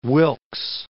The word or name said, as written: Wilkes Land